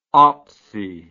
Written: opsy

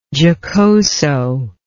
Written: giocoso

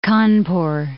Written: Kanpur

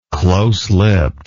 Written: close-lipped

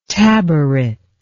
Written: tabaret

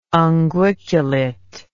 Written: unguiculate

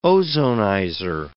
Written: ozonizer

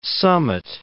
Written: summit

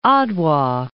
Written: Adwa

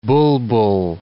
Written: bulbul

